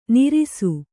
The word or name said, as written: ನೀರಸ